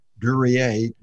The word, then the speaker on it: Duryea: I was calling him Duryea, Duryea. – D-U-R. Let me spell it. D-U-R-Y-E-E. D-U-R-Y-E-E. David A. Duryea. Now you got me mispronouncing it. Duryea.